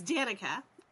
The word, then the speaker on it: Danica: It's Danica.